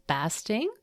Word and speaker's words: Basting: To avoid elder sense of aloneness, Dr. Anne Basting, am I pronouncing that right? – It's basting like a turkey.